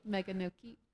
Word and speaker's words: Meganoke: Meganoke.